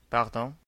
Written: sorry

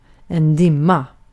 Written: duman

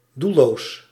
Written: feckless

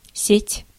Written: network

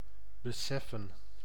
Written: beseffen

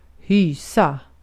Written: hysa

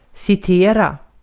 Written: citera

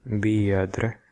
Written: member

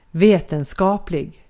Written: vetenskaplig

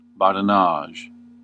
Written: raljeri

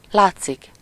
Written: paraître

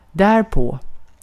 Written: därpå

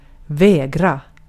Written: vägra

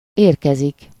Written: ankommen

